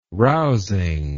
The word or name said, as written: rousing